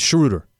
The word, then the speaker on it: Schroeder: By the way, it's Schroeder.